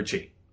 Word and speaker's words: chi: Because, like, your weapon is an extension of your ki. Is it ki or chi? – Chi. Chi. Chi. – Okay. – Yeah, no, chi.